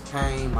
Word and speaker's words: Payne: And it's not pain how you would originally spell it. – Right. – It's Payne, my last name, P-A-Y-N-E.